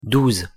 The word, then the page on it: douze: French